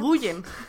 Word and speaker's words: Nguyen: de Jeff Lemire y Dustin Nguyen, o algo así se pronuncia. – Nguyen.